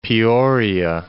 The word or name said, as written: Peoria